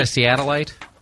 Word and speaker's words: Seattleite: Is that how you say it, a Seattleite? – Yeah. – Okay, good.